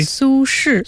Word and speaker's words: Su Shi: Su Shi. – Su Shi. – Right, with the same spelling as Sushi, but yeah, Su Shi.